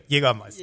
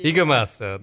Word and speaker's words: Jägermeister: the Currywurst and the Geigermeister, right? – Jägermeister. – Jägermeister, the right way to say it.